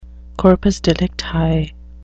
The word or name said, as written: corpus delicti